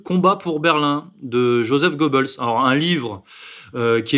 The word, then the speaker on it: Goebbels: Et d'abord, je vous prierai de prononcer Joseph Goebbels et pas Joseph.